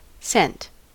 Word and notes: cent: American English

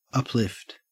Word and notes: uplift: Australian English

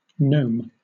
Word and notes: gnome: British English

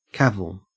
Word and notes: cavil: Australian English

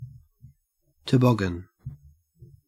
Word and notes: toboggan: Australian English

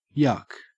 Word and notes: yuk: Australian English

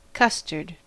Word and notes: custard: American English

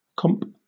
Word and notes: comp: British English

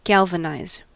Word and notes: galvanize: American English

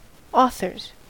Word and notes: authors: American English

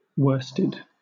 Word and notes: worsted: British English

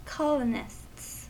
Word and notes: colonists: American English

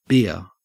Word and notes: beer: Australian English

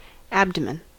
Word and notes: abdomen: American English

